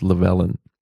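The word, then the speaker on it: Llewelyn: Ja, und vielleicht auch erwähnenswert, in diesem Film haben wir das erste Mal Desmond, jetzt dieser Nachname, da habe ich schon unterschiedlichste Aussprachen gehört, aber ich glaube, es heißt Llewellyn.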